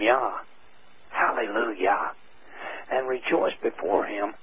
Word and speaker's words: Jah: The one verse in the King James translation which does bring his name over into the English in the form of Jah, pronounced Yah, is by itself enough to be the obedient person to see the responsibility in the book of Psalms 68:4.